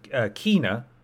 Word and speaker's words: Kena: Kena, I'm assuming that's how it's pronounced.